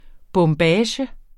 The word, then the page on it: bombage: [ bɔmˈbæːɕə ]; [ bʌm- ]